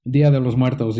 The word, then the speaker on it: Día de los Muertos: And it's all, how do you say, De los Muertos? – Día de los Muertos, yeah.